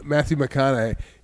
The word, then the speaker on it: McConaughey: McConaughey? – Matthew McConaughey.